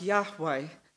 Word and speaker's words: Yahweh: We think that name, I am, in Hebrew was probably pronounced Yahweh. It's a bit like a breath.